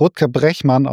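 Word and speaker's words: Rutger Bregman: Ich habe gehört, er ist Niederländer und wird Rutger Brechmann ausgesprochen.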